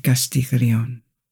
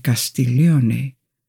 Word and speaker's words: Castiglione: Retrocedamos 60 años en el pasado y descubramos la vida de la condesa de Castiglione, que es su pronunciación francesa, aunque Castiglione sería una pronunciación más apropiada, porque era italiana y obtuvo el título de condesa debido a su corto matrimonio en su adolescencia.